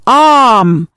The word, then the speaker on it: Om: Khi ba âm này phối hợp lại, sẽ tạo ra âm tiết Aum, còn đọc là Om, thần chú linh thiêng của đạo Hindu và đạo Phật.